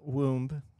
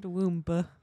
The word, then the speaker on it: womb: Like even babies, like when they're not only when they're inside the womb, but also like when they're growing up. – Did you just pronounce the B? – Huh? – You said womba.